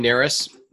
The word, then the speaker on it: Narus: So my name is Ryan Narus. It's spelled N-A-R-U-S.